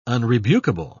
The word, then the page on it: unrebukable: British English